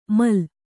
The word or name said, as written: ಮಾಲು